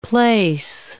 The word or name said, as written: plaice